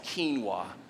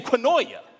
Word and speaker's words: quinoa: The person next to me, she hears it, leans over and says, it's actually pronounced quinoa. And I'm sitting here and I'm like, hold on, for 28 years, it's been quinoa.